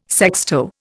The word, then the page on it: sexto: Indian